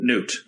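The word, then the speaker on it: Knute: Does one say Knut or Newt? – Newt. Yeah, Newt. No, you don't pronounce Newt. The K.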